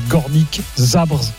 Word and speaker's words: Górnik Zabrze: Je ne sais pas si je prononce bien, le Górnik Zabrze.